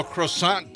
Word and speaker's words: croissant: So how do you say croissant in French with the typical French pronunciation? – That's is it. Croissant.